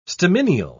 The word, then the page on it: stamineal: British English